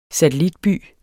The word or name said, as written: satellitby